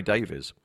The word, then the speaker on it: Davies: At the outset here, Davies is pronounced Davis in Britain, right? – Hunter Davies. – David. Well, Davies they say in America, but I've heard that in Britain, like Ray Davis, that's how it's pronounced. – No, I would say Ray Davies.